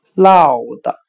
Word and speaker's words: loud: 我们用谐音法来记这个单词 loud，它的发音很像汉语的唠的唠，是指唠嗑的唠。